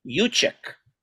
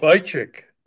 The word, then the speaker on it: Juszczyk: But it's J-U-S-C-Y. I think it's pronounced Juszczyk. I thought that was unusual. – Juszczyk.